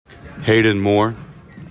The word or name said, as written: HAYDEN MOORE